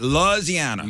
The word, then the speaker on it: Louisiana: Louisiana.